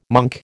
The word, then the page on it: monk: American English